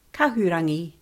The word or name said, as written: kahurangi